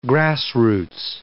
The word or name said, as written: grassroots